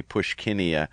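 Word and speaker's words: Puschkinia: And then I'm so excited that you ID'd that one plant for me, Pushkinia.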